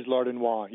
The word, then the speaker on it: Lardinois: It is Lardinois. You were correct. – Okay, so it sounds French. – Yes, right. It is French.